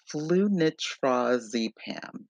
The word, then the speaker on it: flunitrazepam: And then there is rohypnol or flunitrazepam, flunitrazepam. Oh gosh, I'm really butchering these names, but flunitrazepam, that's it.